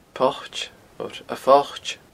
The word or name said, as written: poit